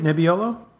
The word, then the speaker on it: Nebbiolo: And he brought a bottle of 08 Nebbiolo. – Okay. – Did I say that right? Nebbiolo? – Yeah, I think so.